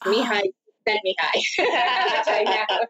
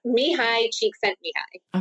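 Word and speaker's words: Mihaly Csikszentmihalyi: There's several YouTube videos that will tell you it's Mihai Csikszentmihalyi. – Awesome. Can you say it one more time? – Mihai Csikszentmihalyi.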